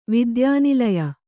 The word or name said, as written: ವಿದ್ಯಾನಿಲಯ